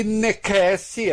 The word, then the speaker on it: necesse: teria dito: navigare necesse est. Necesse se escreve com C, mas se lê K, muito provavelmente.